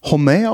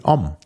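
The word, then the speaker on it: Homme: Nee, Om. Om. Om. Mann. Om wie Om. Mann. Om. Okay. OM einfach nur ausgesprochen. OM. Om. Okay. Das H ist rein etymologisch, weil man so kleinisch mal ähm, Homo mit H mhm. – äh, gesprochen hat, irgendwann mal ganz früher.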